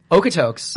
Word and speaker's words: Okotoks: Okotokies? Sure. How do you say it? – Okotoks. – Okotoks.